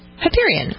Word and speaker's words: Hyperion: How would you say it? – Hyperion. – Oh, that was right the first time.